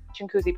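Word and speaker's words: Chinguacousy: Like, one of them was, like, I grew up in Brampton, so there's Chinkoozie Park. And it's spelled Chingowakauzi, but it's one of those things that you don't think about it when you, that's such an English speaker thing to do too. It's like, you don't think about it because the spelling doesn't make any sense, but that's just how you pronounce it.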